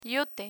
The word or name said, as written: yute